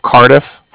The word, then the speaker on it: Cardiff: Okay. – I'm calling with the Bank of Cardiff. – Okay. – And that's spelled C-A-R-D-I-F-F, as in Frank. So it's like car and then different.